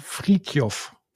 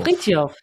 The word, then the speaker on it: Frithjof: Ähm, ist der ähm, Friedhof oder Friedhof? – Friedhof. Friedhof. – Friedhof, ja.